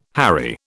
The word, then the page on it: harry: British English